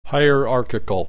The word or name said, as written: hierarchical